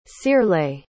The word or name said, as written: searlait